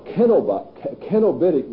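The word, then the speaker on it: cenobitic: Then we come to Kenobitic monasticism, giving it the Latin hard C sound.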